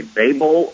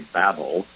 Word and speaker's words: Babel: You can say Babel or you can say Babel. Either way, it's B, B as in boy, B-A-B-E-L, Babel or Babel.